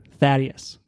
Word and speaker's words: Thaddeus: You pronounce it how it's spelled. – Yeah. – Thaddeus.